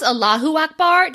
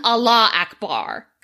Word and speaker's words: Allahu Akbar: Honestly, so he read through it to make sure this was coherent, and his only thing was like, I know it's pronounced Allahu Akbar, just make sure you're pronouncing it correctly. – How were you saying it? – Well, it was written Allah Akbar, because like Allah, yeah. Like, but it's Allahu Akbar.